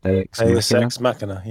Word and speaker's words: Deus Ex Machina: Davis is that he usually ends in what they call, I think, I can't say it properly, but Deus Ex Machina, yeah.